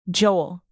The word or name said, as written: joel